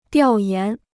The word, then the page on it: 调研: diào yán